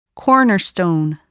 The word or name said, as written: cornerstone